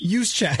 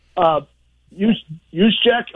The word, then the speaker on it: Juszczyk: But if you look at the guy from the 49ers, and I'll never be able to say his name right, Kyle Juszczyk or whatever his name is. – Juszczyk. – That's... – Well, try that again. – I can barely pronounce my own last name, which has a lot of consonants in it also. Juszczyk? Okay. – Yes, there you go.